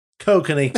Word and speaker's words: Kokanee: Cocainy. – Kiss my ass. That's another thing Canadians don't do right. It's fucking cocaine. – American pronunciation. – Yeah, that's the Kokanee. Canadian pronunciation.